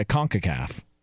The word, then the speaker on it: CONCACAF: Yeah, CONCACAF.